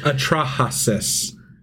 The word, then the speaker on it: Atrahasis: Next one, Atrahasis. – Atrahasis? – Atrahasis. – Hasis or Hosis? – Atrahasis. – Hasis. Okay. – Yeah, Atrahasis. – Atrahasis.